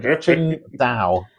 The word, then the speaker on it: Qingdao: At one point, they had a whole marketing campaign specifically on the UK about how to pronounce the name Qingdao as well. So phonetically, like Qingdao.